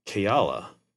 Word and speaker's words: Kaela: I liked the K-A-E-L-A pronunciation of it, the spelling of it. – Okay. Okay. – Well, I looked it up later, and it's like Hawaiian origin, and it's pronounced Kayala.